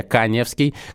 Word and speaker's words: Каневский: Инстаграм у меня с галочкой, фамилия пишется через А. Не устаю об этом говорить, говорю об этом всю свою сознательную жизнь, потому что меня вечно конем склоняют, конем, а я Каневский, как Леонид Семенович э, Каневский, э, легендарный э, актер театра кино.